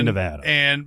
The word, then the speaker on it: Nevada: I've always said Nevada, and that's wrong. – Yeah. The locals say Nevada.